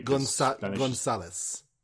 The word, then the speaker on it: Gonzales: Gonzales.